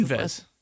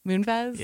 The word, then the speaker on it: Moonves: And actually, Les Moonves, I don't know exactly how to pronounce Moonves. – Moonves? – Yes.